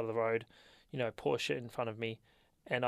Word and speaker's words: Porsche: It was a Porsche in front of me. Porsche! As the Americans call it, Porsche. They pronounce the E as a sound.